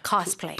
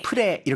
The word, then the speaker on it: cosplay: Cosplay. Yeah, play까지 play. – 해야죠. – Yeah, cosplay. – 프레 이렇게 끝내면 안 되는 거죠.